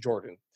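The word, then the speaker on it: Yordan: And if people wonder why am I calling him Jordan? Because apparently Jordan came out and said that his name is pronounced Jordan.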